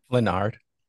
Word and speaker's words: Flenard: How do you say your last name? Flanard? – Flanard. – I got it right.